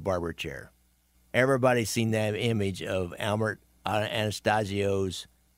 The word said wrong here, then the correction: Anastasia: Anastasia. Now, I've said his name right, or the original way it was spelled.